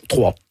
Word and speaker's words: Troyes: On dit Troyes en France. Comment on prononce Troyes. – Troyes. Mais voilà, ça s'écrit Y.